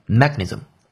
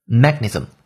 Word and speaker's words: mechanism: 那我们再回到这个句子当中，说 made it possible 使一件事情变得可能，去 elucidate the mechanisms 一种机制，underlying this phenomenon 是在这个现象背后的一种机制。注意，mechanism 这个单词的重音在第一音节，不在第二音节。错误的读音是 mechanism，这是错的。正确读音为 mechanism。 mechanism 机制、原理这个单词，它的拼写为 m e c h a n i s m，可以参考讲义来看到这个单词。